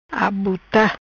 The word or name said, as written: Abuta